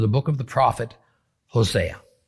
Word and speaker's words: Hosea: Now, in the book of Hosea, actually pronounced Hosea, chapter 1, we get a curious story about Hosea being ordered to marry a harlot, a prostitute named Gomer.